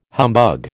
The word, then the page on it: humbug: American English